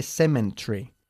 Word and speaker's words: cemetery: Okay, bien, no se dice cemetery o cemetery. No hay N en la palabra cemetery, okay? – Cemetery.